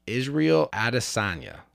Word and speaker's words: Israel Adesanya: And I did see this on UFC, Israel Adesanya.